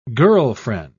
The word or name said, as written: girlfriend